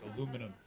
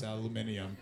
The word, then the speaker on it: aluminium: Even though aluminum was invented in North America, you guys mispronounce it and say, well, we spell it correctly, which is impossible because when we invent something, we get to name it. You know what I'm saying? That's the way it just, it drives me so crazy. And all you guys give me this patronizing, yeah, we know, but still, fuck you. It's aluminium. Say correctly, or you'll never find it in the Sainsbury's.